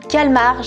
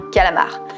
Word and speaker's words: calmar: Un calmar, pas un calamar. Non, parce que quand j'ai dit ça mon mec, il a rigolé sous prétexte que comme je suis 10, je prononce mal les trucs et il m'a dit ah, on dit un calamar. Non, on dit bien un calmar géant.